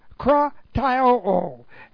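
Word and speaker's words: Krataio (κραταιόω): It's an aorist passive, and that means, as you know, that we'll receive it, of the verb krataio. And this looks like this. And it's not a familiar word. Okay, this is K-R-A-T-A-I-O, long O.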